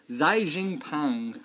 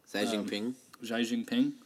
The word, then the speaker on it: Xi Jinping: But I mean, Donald Trump got his got involved and even called the Chinese president Xi Jinping. I think that's how you pronounce it. I probably butchered it. – Xi Jinping for help, and then the president.